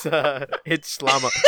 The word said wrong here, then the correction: Shyamalan: Oh no, now I'm saying it incorrectly. Damn it, Zach. Shyamalan.